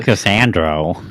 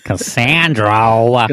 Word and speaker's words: Cassandro: I know inside you're dying at my pronunciations. – Just a Cassandro. Cassandro. Cassandro. Right.